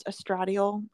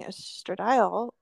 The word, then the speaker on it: estradiol: Which I always want to pronounce estradiol. I don't know why it's mm-hmm. estradiol, whatever.